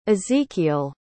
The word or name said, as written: ezequiel